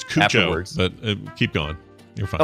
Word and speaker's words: Cuoco: I don't know if you know, but Kaylee Cuoco from The Big Bang Theory.